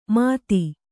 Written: ಮಥಿ

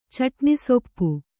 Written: ಚಟ್ನಿಸೊಪ್ಪು

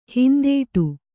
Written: ಹಿಂದಡಿ

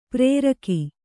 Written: ಪ್ರೇರಕ

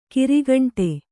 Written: ಗರಿಗಂಟಿ

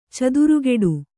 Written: ಚಿತ್ರಕುಟಿ

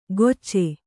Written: ಗೊಚ್ಚೆ

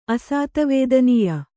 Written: ಅಸಾತವೇದನೀಯ

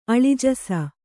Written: ಅಳಿಜಸ

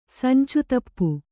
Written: ಸಂಚತಪ್ಪು

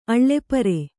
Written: ಆಲಿಪರಿ

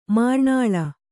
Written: ಮರನೆಲ್ಲಿ

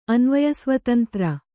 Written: ಅನ್ವಯಸ್ವತಂತ್ರ

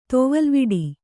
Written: ತೊವಲ್ವಿಡಿ

